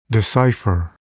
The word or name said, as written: decipher